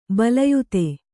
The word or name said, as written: ಬಿಲ್ಲಾಯ್ತ